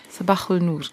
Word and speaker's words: Saba-Nur: Die Aussprache wäre noch ein bisschen anders. – okay, ja. wie wäre die? – Sabahul Nur.